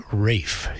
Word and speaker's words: Ralph: And I learned that a well-known British composer by the name of Vaughan Williams, his first name R-A-L-P-H, and I did a lot of research and found out that, yes, we should pronounce it Rafe. His wife said so late in her years, and his good friend, Sir Adrian Bolt, also on a film referred to him as his good friend, Rafe Vaughan Williams.